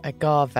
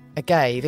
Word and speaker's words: agave: Oh, you said that the posh Spanish way, agave. I always thought it was agave.